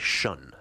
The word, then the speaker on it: Sion: From Kansas City, that was Sion, or actually Shun, as it's pronounced, double S-I-O-N.